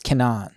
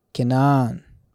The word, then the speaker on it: Kenan: It's Kenan. Kenan. Because it's in Hebrew, the accent is always on the last syllable, unless there's an accent there saying it's in the syllable.